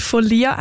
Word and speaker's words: Follia: Mit sanften Klängen begleitet sie da euch durch den Mittwochnachmittag durch das Female Music Special, die Folia. Folia, jetzt habe ich es gerade falsch gesagt, stimmt. Oh, das habe ich gerade schwätzen. F-O-L-L-I-A schreibt man. Und Folia, hast du mir verraten, sagt man.